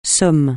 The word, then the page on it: somme: francais